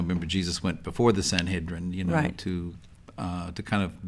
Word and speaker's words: Sanhedrin: The Sanhedrin. – Sanhedrin.